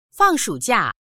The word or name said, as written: fang4shu3jia4